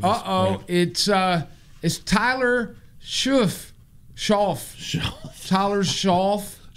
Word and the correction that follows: Shough: It's like cough. And everybody in chat said it's pronounced Shuck. – I hate the Rippers and the Shippers. – Tyler Shuck, not Schauff, but his last name is spelled S-H-O-U-G-H.